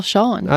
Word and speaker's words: Sean: The art is from Sane S E A N. Is that Sane Shane? Sean. I'm pretty sure that's a way to spell Sean. – I think it's pronounced Sean.